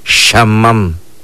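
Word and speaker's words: xaman: Norte, ¿se acuerdan cómo pronunciamos norte en lengua maya? Nosotros lo pronunciamos de esta manera. Shamam. Shamam es norte.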